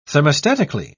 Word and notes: thermostatically: British English

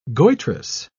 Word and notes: goitrous: British English

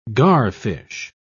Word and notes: garfish: British English

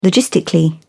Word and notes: logistically: British English

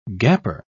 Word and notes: gaper: British English

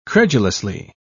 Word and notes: credulously: British English

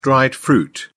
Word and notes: dried fruit: British English

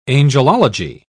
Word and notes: angelology: British English

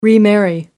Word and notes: remarry: American English

